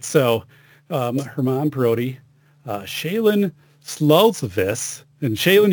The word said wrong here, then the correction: Sluzalis: Oh, Shailen Sluzalis.